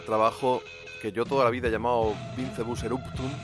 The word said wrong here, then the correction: Vincebus Eruptum: pero acabo de descubrir que se pronunciaría algo así como Vincebus Eruptum. O sea, voy a llamarlo como en pseudo latín, Vincebus Eruptum, no hay duda.